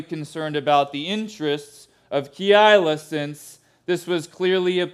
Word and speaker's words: Keilah: Keilah can also be pronounced Keilah. Fancy people will prefer Keilah. I'm not that fancy. You might hear me say Keilah a few times.